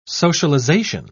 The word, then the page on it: socialisation: British English